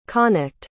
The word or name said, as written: connacht